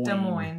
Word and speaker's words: Des Moines: Des Moines. – Des Moines.